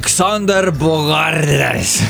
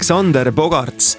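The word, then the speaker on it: Xander Bogaerts: On seda korduvalt teinud ja ta on üks parimaid pesavallureid Arubalt pärit mees Xander Bogarts. – Tegelikult on Xander Bogarts. – Bogarts, ta hääldatakse, veidi Hollandi päraselt, et ma pisut uurisin seda tausta ka.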